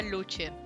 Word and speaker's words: Lucien: Según el libro, en teoría se pronunciaba Lucien. pero creo que es más cómodo decir Lucien.